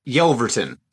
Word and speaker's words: Yelverton: Oh, there's only one Jared in the group, but how do you say your last name so that when people hear this, they know exactly who we're talking about? – Yelverton. – All right. There you go, guys. Now you know. – And that's why I was avoiding it. – It's okay. It is honestly exactly how it's spelled.